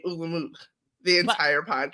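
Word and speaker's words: Ole Munch: Ol' Munch making biscuits together? – I believe he gives a correct pronunciation for his name, which is Ulla Munk. He's Ulla not Ol' Munch. He's Ulla Munk.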